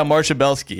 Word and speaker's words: Marcia Belsky: But your first clip, Marcia, is that, I'm saying that right? – Yeah, Marcia Belsky.